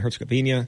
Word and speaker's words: Herzegovina: They take on Bosnia and Herzegovina. Am I saying that right? – Yeah.